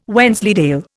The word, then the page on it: Wensleydale: Indian